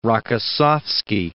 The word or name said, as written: Rokossovsky